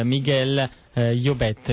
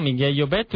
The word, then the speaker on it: Miguel Llobet: Miguel Llobet, mi corregga se sbaglio la pronuncia perché è catalano. – No, la pronuncia è giustissima, la pronuncia in catalano perfettamente. – Infatti, ecco, catalano Miguel Llobet,